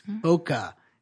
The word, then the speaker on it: bokeh: B-O-K-E-H is how it's spelled. Bokeh is how it's pronounced.